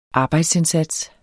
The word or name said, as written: arbejdsindsats